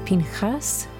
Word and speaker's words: Pinchas: Our Torah portion this week is Pinchas, which is the Hebrew pronunciation of the name Phineas.